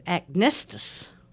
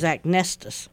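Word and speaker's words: acnestis: It's agnestus. – I think that's a perfect name for that spot. – It starts out spelled like acne, but the emphasis is agnestus.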